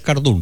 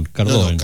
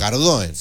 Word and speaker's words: Cardoen: Sí, a propósito de racimo, está bien pronunciado Cardoen, ¿no? No es Cardún, Cardoen. – No, Cardoen, sí.